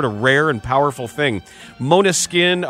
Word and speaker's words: Måneskin: The unlikely global ascent of Måneskin, the word is Danish for moonlight, pronounced Måneskin,